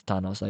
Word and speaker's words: Thanos: Is it pronounced Thanos? – No, it's Thanos. I just said it Thanos.